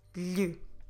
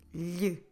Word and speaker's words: lhe: L-H-E. Lhe. I know it's not the easiest sound, but if you remember words that you might have learned before, like son and daughter, filho, filha, these words have the exact same L-H.